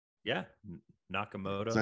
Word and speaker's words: Nakamoto: About 2 weeks after that, that's when Nakamoto came up with. Am I saying that right? Nakamoto? – Yeah, Nakamoto. – I said it wrong before, but that's when he came up with this whole idea.